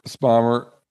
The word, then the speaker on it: Spomer: Ron Spomer, Spomer, Spomer, Ron rhymes with Homer Spomer.